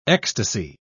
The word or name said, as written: ecstasy